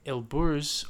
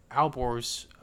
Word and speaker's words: Alborz: Then to the north, you have the Elburz or Alborz Mountains, depending on the pronunciation.